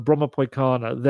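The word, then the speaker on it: Bromma Pojkarna: I think the J in it is pronounced more like a Y, someone told me. So Broma Poikana, their academy, they have like 4,000 people or players within it.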